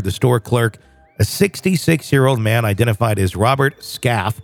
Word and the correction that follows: Skafty: Did I say it right? – Skafty, I'm sorry.